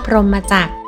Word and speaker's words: พรหมจักร: ไม่เห็นอากาศกอไก่รอเรืออ่านว่าพรหมจักแปลว่าจักอันประเสริฐวงล้ออันประเสริฐพรหมจัก